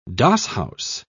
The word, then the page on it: doss-house: British English